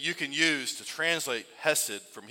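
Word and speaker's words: hesed (חֶסֶד): In the Old Testament, there's a Hebrew word that's pronounced hesed.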